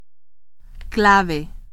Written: clave

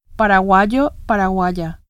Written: paraguayo

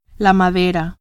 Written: madera

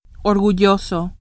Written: orgulloso

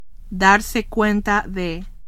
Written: darse cuenta de

